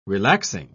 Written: relaxing

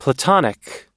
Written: platonic

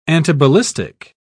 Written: antiballistic

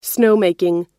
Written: snowmaking

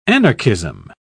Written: anarchism

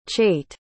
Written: chait

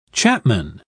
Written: chapman

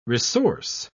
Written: resource

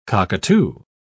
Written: cockatoo

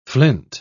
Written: flint

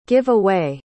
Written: give away

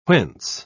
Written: whence

